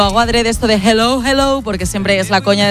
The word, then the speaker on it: hello: Y la segunda, dile a Elena que su dicción en castellano es la más perfecta que conozco, pero que cuando diga hello, que no pronuncie la J, que no es hello, que es yeah. Hello, que la aspire la J y no pronuncie la J potente castellana.